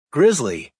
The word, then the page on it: grizzly: American English